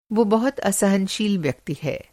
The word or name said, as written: असहनशील